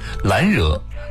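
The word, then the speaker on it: 兰若: 第五六句，懒惹静复静，茅茨深又深，先来解释两个词。懒惹，兰花的兰，惹字呢是若隐若现的若，这里是梵语的读音，广义上指的是禅修的寂静之处，这里代指树林。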